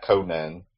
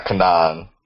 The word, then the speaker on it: Kanan: Real quick, as Roddy Cat lets me know, the pronunciation I had wrong is Kanan, not Conan, not Kanan.